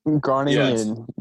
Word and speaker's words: Groningen: And I said, what did I say? Groningen? – Groningen, yes.